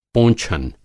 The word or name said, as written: पोंछन